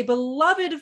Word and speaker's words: beloved: Howard is a beloved figure. I'm sorry, is it beloved or is it just beloved? How do you say that word? Beloved. Fantastic. – Not that way. It's much more impressive.